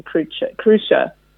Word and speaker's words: Cruxia: The Mapmakers in Crucia. It's spelled Cruxia, but it does tell you through the book how to say it, Crucia.